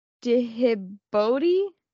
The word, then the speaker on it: Djibouti: And Djibouti.